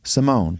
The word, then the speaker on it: Simone: Simone.